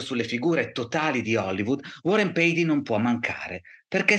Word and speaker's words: Warren Beatty: probabilmente non vi diranno mai Warren Beatty, ci dice lui che si pronuncia così.